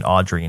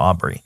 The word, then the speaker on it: Aubrey: My brain, as I'm saying her name, always, it's always like replacing the B with the D and pronouncing it Audrey instead of Aubrey.